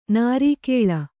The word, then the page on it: ನಿರ್ಗಾಳಿ: nirgāḷi